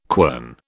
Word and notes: quern: British English

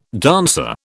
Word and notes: dancer: British English

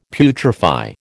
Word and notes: putrefy: American English

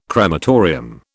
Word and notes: crematorium: British English

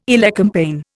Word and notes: elecampane: Indian

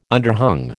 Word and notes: underhung: American English

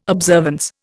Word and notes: observance: Indian

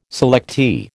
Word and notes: selectee: American English

